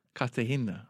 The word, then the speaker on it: Cartagena: Is it Cartagena that you've visited as well? That's got a bit of film history. – Cartagena. – That's okay. The first time you've pulled us up on a pronunciation.